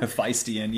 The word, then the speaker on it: Hephaestion: Hephaestion, yeah, they were kind of Jared Leto.